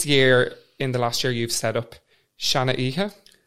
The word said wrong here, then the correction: Shana Kiha: Am I pronouncing it correctly? – Almost. – Oh, go on, tell us all. – It's pronounced Shana Kiha.